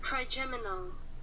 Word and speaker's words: trigeminal: Trigeminal. – Huh? – I was just looking up how to pronounce that word. – Trigeminal.